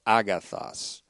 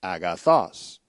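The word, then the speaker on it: agathos (ἀγαθός): Chrysona is actually, and again, this is a little bit confusing, but it's, strangely enough, the comparative use of the adjective agathos, A-G-A-T-H-O-S, agathos, or agathos. Actually, agathos. I put the accent on the wrong syllable here, but agathos, meaning good or beneficial.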